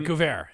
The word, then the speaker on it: Guevara: Yeah, he's fighting Sammy Guevara. Am I saying that right? – Close enough. – Yeah. So. Guevara.